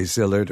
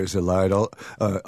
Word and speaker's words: Szilard: Leo, yes, you can say Szilard or Szilard. Almost everyone in this book wanted to become American, so we can pronounce them with as American accent as we want.